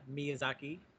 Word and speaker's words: Miyazaki: I'm glad that Bigfoot was here to what's the proper pronunciation on that? – Miyazaki.